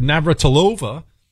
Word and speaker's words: Navratilova: Navratilova.